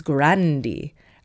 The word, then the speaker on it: Grande: And similarly, Ariana Grande, apparently Grande is not meant to be pronounced like it is at Starbucks. It's Grandi, Ariana Grande.